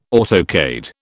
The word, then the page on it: autocade: British English